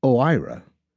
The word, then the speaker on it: OIRA: OIRA, pronounced OIRA, is Washington speak for a little office with a big impact.